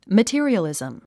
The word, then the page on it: materialism: mətí ə riəlìzm; マティリアリズム